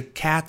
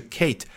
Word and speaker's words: Kat: 为什么是 Cat 而不是 Kate？实际上没有规则，原因非常简单。